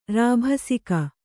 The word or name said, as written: ರಾಭಸಿಕ